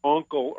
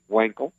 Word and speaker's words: Wankel: The Uncle or Wankel, I guess the Americans say Wankel, powered rotary Corvette.